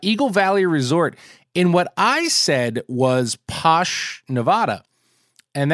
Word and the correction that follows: Pioche: that was supposed to be pronounced Pioche. Pioche? I still don't really know how to pronounce it. Pioche. Pioche. Pioche. That's what it is.